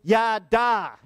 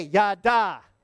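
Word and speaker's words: Yada (ידע): The first one is Yada. Everybody say Yada.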